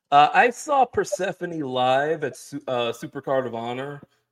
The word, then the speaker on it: Persephone: Who just won a championship tonight in RevPro, a Scottish championship, so she's collecting them all, versus Alex Windsor, versus Persephone. I don't think I ever say that right. – Persephone, sorry.